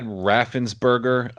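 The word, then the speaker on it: Raffensperger: And a lot of people are really sensing blood in the water on our side now, because they all know that Ratzenberger is known about this, or whatever the guy's name is. Am I saying his name right? Is it Ratzenberger? – Brad Raffensberger.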